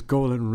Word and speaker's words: Golan: Because Golan rhymes with colon.